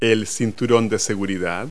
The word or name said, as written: seatbelt